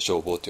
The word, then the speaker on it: 正法: だからちょっと世間の読み方とお寺の読み方が違ってきちゃうので、で、ここでは正しい方とやって正法って読むんですけど、それはね、置いといて。